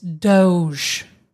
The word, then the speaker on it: Doge: It's Doge. Doge is how you say it.